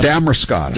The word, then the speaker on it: Damariscotta: The hunt for Sasquatch in, how do you say it, Damariscotta? – Damariscotta.